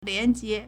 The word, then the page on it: 廉洁: liánjié